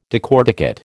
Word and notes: decorticate: American English

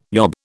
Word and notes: yob: British English

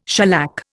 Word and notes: shellac: Indian

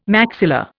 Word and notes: maxilla: Indian

